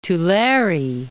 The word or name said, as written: Tulare